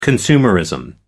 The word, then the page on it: consumerism: American English